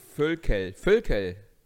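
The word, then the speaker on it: Voelkel: Völkel. – Ja, Völkel. Mensch doch. Anstatt ein Ö ist dann O-E.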